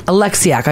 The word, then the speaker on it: Oleksiak: For women, Leila Fernandez in tennis, Bianca Andreescu in tennis, and I never pronounce her last name correctly, Penny Oleksiak. – Oleksiak. – I almost got it.